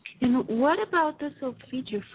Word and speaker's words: solfeggio: It's solfeggio, S-O-L-F-E-G-G-I-O.